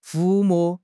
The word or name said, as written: 抚摸